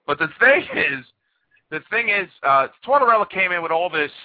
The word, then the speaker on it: Tortorella: How do you pronounce his name? – Tortorella. Like mozzarella, Tortorella.